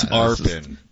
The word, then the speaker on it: Arpan: And then Basu just corrects him like, buddy, you're a professional broadcaster? – It's Arpan.